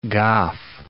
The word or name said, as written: Gough